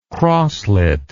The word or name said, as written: crossleted